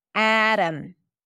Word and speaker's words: Adam: Adam, Adam. So the T is a flap T because it's between two vowels in an unstressed syllable. And then the O is reduced because the syllable is unstressed.